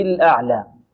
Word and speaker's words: الأعلى: الأعلى لا نقول الأعلى أعلى أعلى لا، أو الأعلى أعلى، البعض ينطق هكذا يفخم العين الأعلى، هذا خطأ، وربما يفخم معه الهمزة أعلى ربه الأعلى، لا خطأ، الأعلى الهمزة مرقة والعين مرقة ومتوسطة، الأعلى أعلى مقدار حركتين الألف.